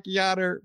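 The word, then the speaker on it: Yainer: It looks like Yonair, but it is with a J, Jean. It's more like a Yonair Diaz, from what I understand.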